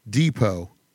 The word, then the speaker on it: depot: What silent letter ends these words? Ballet, debut, depot. – T.